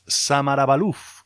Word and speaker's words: Samarabalouf: Como casi siempre, con un montón de novedades discográficas para abrir el programa, la de este trío basado en tierras francesas, Samara Balouf. Es como se escribe.